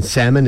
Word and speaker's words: salmon: things like learning that the L in salmon is silent.